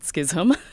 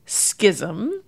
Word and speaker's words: schism: Now, you and I are both using the pronunciation schism, but the traditional pronunciation of this word is schism. You will find this in the Oxford English Dictionary, Merriam-Webster, American Heritage. And it is the traditional pronunciation.